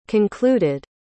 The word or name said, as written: concluded